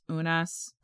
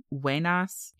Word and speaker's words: Unas: The name of that exhibit was the Tomb of Unas, often pronounced Unas, probably actually Wenas, in the Field Museum in Chicago.